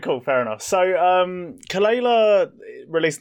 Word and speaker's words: Kelela: Somehow you managed to pronounce Sanguisugabogg fine, but you mispronounced Kelela. – Oh, it's Kelela.